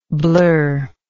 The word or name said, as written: blurredness